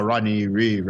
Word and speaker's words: Rodney Reed: From what I saw, this was about Rodney Reed, right? – Yeah.